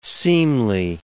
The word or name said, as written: seemly